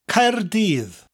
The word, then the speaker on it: Caerdydd: Spelled C-A-E-R-D-Y-D-D. The first syllable ends with a rolling R, Caer, and the double D at the end of the second syllable gives us a Th sound. Dydd.